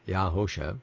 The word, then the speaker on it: Yahosha: Yahusha is spelled Y-A-H-O-S-H-A, Yahusha, as opposed to those who are still using the Shua, who have not studied about that, the corruption.